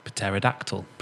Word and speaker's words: pterodactyl: But it's helico, which means spiral, and P-T-E-R, like pter, meaning one with wings, like pterodactyl, with the silent P.